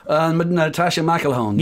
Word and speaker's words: Natasha McElhone: It was Robert De Niro was driving a Peugeot, and it was a Natasha McElhone. Am I saying that right? – Natasha McElhone. – Yeah, McElhone. Yeah, she was driving the M5.